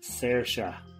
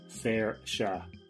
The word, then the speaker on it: Saoirse: Just the pronunciation of that actress's name is Saoirse. Saoirse. Not Saoirse. I think you were going Saoirse, which is the way that really posh people in Ireland would say, like Saoirse Ronan. No, it's Saoirse.